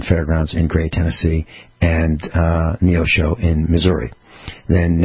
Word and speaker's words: Neosho: And they've got some shows this weekend, February 8th and 9th, Neo Show, Missouri. N-E-O S-H-O, pronounced Neosho, Missouri.